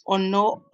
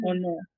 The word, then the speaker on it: Onor: My name is, like, my first name is Onor Obasi, but you can just call me Onor.